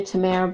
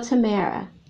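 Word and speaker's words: Tamyara: So you can look me up at um, under Tamara Brown, and my name is spelled differently.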